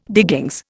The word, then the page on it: diggings: Indian